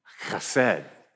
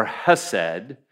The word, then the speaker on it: Khesed: Khesed. Now, you'd be tempted to say Khesed or Hesed, but really, you got to say it like you have a hair stuck in the back of your throat.